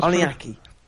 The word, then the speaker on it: Oninaki: Is it Onaki? Onaki? – Oninaki? I guess that was Oninaki.